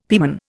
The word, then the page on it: pieman: Indian